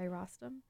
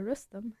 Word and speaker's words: Rostam: Prior to that track, you all heard Wood by Rostam, or Rustam, if you want to get that pronunciation.